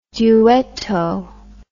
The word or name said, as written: duetto